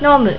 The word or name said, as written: nomu